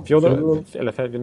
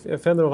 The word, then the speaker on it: Fedorov: Ja, Fedorov, nu sa jag ryska uttal här. Nej, Fedorov, han har ju vunnit många Stanley Cup, så han har väl ändå en tusental poäng i NHL.